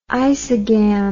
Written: isogam

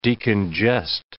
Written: decongest

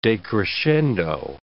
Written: decrescendo

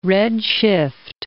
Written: redshift

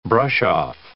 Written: brush-off

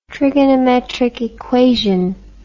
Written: trigonometric equation

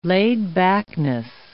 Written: laid-backness

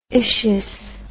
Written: itious

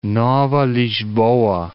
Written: Nova Lisboa

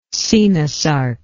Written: coenosarcous

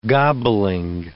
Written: gobbling

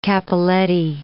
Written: cappelletti

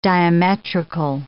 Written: diametrical

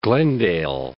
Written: Glendale

